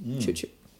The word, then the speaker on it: CXCX: And CXCX is an acronym of my actual Chinese name, which is spelt with a C and an X, partly. So that drawn together, CXCX, Choo Choo.